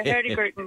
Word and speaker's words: Hurtigruten: Hurtigruten, yes.